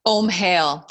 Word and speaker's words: Omhale: I said Om Wellness, but how do you pronounce it? – Omhale. So think about inhale, exhale.